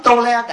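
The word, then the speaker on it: tôle: Cái tôi nói tua le là ổng nè, tua le là cái tấm tôn đó, tại vì hồi xưa cái chữ tôn là chữ tole thì nó đọc là cái tấm tua le đẩy cho có ri ke, ri ke là rice đó, cái bao gạo là rice.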